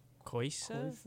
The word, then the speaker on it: Coisa: Kosa, how would you pronounce this? C-O-I-S-A Mandona. – Koisa? – Koisa Mandona. Koisa.